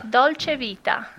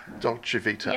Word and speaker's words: Dolce Vita: So what is the pronunciation of Dolce Vita? – Dolce Vita. – Dolce Vita. – Yes.